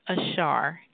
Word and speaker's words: Ashar: The name of the pronunciation of the practice is Ashar, Counseling and Psychological Ashar.